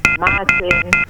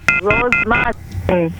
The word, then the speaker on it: Martin: Martin. – Rose Martin? – Rose Martin. – Oh. Spell that, Martin. – M-A-R-T-I-N.